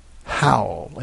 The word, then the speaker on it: Howell: Pam said, the man whom I knew as uncle, her husband, was named Rex Howell, pronounced Howell, H-O-W-L, spelled H-O-W-E-L-L. And Howell, rhymes with foul, she said.